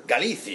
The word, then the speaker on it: Galicia: Galicia or whatever. – Galicia. Yeah, hombre, please. – Do you know that? That's the pronunciation, right? – Galicia.